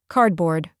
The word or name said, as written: cardboard